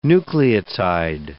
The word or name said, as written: nucleotide